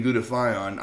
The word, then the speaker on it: glutathione: And of course, we're like, what? Glutathu? And like I said, it took me forever to figure out how to pronounce it. And once they explained to me the glutathione, I wasn't real a big fan.